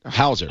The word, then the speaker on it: Hauser: It had Paul Rouser. Am I saying his name right? Hauser. – Paul Walter Hauser.